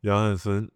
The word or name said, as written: Hansen Yang